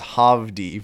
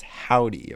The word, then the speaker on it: Hovvdy: And one of the bands was Howdy, which is spelled H-O-V-V-D-Y. – Howdy. – So I thought it was Hovdy for the longest time, but Hovdy. – They're from Hovdy. Texas, so it's Howdy, apparently. – Howdy, y'all. – Howdy, y'allin.